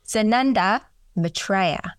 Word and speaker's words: Sananda Maitreya: Sananda Maitreya.